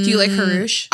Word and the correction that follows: Janusz: I love... – Is that you say his name? – Janusz. – Oh, Janusz. I'm sorry.